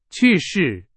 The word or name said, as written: 去世